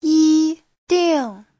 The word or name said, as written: yi1ding4